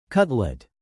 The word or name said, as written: cutlet